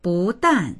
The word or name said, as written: bu2dan4